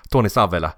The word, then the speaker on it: Tony Savella: Och hur ska ditt namn uttalas då? Savella? Tony Savella är väl, är väl det korrekta uttalet.